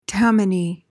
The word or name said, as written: Tammany